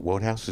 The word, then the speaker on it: Wodehouse: Is it Wodehouse? Is that how you say it? – Mm-hmm.